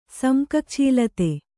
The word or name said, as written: ಸಮ್ಕಕ್ಛೀಲತೆ